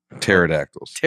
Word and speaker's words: pterodactyls: Dusty very clearly said, I think pedactricals. Oh, pterodactyls. – There's another silent P in there.